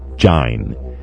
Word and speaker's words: join: He's rhyming line and join, and actually in the Caribbean still to this day the word join is pronounced jine, as to rhyme with line. So that's the old English pronunciation of the word join, jine.